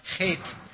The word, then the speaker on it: Chet: Now, you might remember that every letter, every verse of this stanza begins with this Hebrew H, this hard H that's pronounced chet.